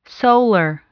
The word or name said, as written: solar